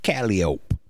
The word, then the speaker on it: Calliope: And Calliope Street in New Orleans is pronounced Calliope.